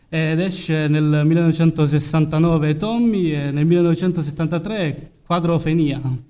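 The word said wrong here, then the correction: Quadrophenia: credo che sia questa la pronuncia. – Quadrofinia, come ci suggerisce il buon Alessandro, il buon Alefonico qui al PC.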